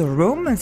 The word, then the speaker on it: Roam: superbe musique qui nous ferait un petit peu planer, c'est Louise Rome, c'est ça, ça s'écrit R-O-A-M.